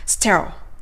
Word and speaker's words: sterile: ということで、無菌状態のという形容詞は、 sterile, sterile ですね。では、ここからは発音練習をしていきましょう。さて、それでは発音練習です。 sterile の発音で難しいのは、後ろの roll のところだと思うんですよね。